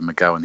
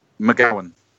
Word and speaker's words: McGowan: And how do you pronounce his last name again? – McGowan. – We're having some trouble with that. – Yeah, we struggled. – that's typically Scottish, yeah. McGowan, that's right, yeah.